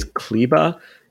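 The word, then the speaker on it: Kleber: And so I'm looking at the probable starters, and they've got Luka, Wes Matthews, Harrison Barnes, Maxi, I'm going to say Kleba, although I think it's well done. – The supposed pronunciation, I think they say is Kleba, which isn't at least that far, but Klebstoff is glue in German.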